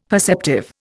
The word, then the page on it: perceptive: Indian